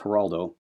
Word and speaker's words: Geraldo: He, as some of my friends pronounce his name, Geraldo or Geraldo. It's Geraldo.